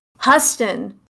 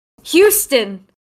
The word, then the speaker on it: Houston: That how you pronounced it. Huston. – Yeah. – Houston. Huston. Yeah. Houston. – Yeah. – None of us questioned that as it not just being Houston.